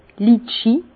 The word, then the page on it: d' Litchis: Bas Rhin; Herrlisheim